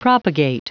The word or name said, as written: propagate